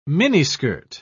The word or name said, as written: miniskirt